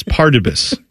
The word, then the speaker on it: Pardubice: Pardubis.